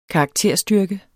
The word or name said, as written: karakterstyrke